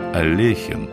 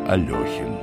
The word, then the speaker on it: Алехин: Звали его Александр Олехин, хотя многие произносят его фамилию как Алехин.